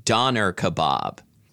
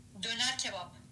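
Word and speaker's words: Döner kebab: Google says American pronunciation Doner kebab, but let's see British pronunciation Dona kebab. How do you say it in Turkish? Okay, here we go. – Yeah. – Doner kebab. Doner kebab.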